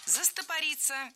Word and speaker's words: застопориться: Нужно просто жесткой рукой взять и начать работать над чем-то другим, чтобы просто не застопориться в каком-то моменте, чтобы самому себя не демотивировать. – Застопориться – неверно. Правильно, застопориться. И здесь уже совсем другой тип ударения. Ударным должен быть корень слова «застопориться».